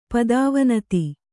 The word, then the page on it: ಪದವಿನತ: kannada; pada vinata